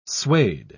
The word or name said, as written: suede